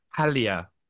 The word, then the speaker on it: Paglia: Palia. You don't pronounce the G. Camille Paglia says.